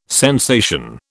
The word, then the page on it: sensation: British English